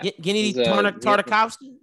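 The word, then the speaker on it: Gennady Tartakovsky: Yeah, Gennady uh, Tarn- Tarnakovsky.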